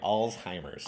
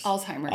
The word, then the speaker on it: Alzheimer's: That way, Alzheimer's. – Alzheimer's. – Alzheimer's. Alzheimer's. But I know there's a lot of like really promising stuff coming out with Alzheimer's.